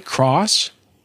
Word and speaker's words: Cross: Cross.